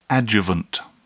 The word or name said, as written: adjuvant